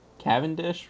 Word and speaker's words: Cavendish: Cavendish would be the right pronunciation.